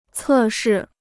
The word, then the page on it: 测试: cè shì